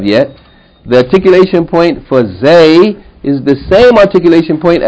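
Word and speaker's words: Zay (زاي): Zay, yani Za Fata Aleph Ya, Zay, or Za'un. Okay, so this letter Zay is pronounced like Z in Zu.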